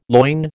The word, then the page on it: loin: American English